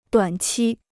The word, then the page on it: 短期: duǎn qī